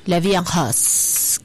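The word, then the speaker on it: La Vie en Rose: Se pronuncia en francés La Vía Rose.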